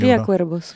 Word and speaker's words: Cría Cuervos: Le V, on dit bien B en espagnol. – Cría Cuervos, oui.